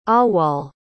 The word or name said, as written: awwal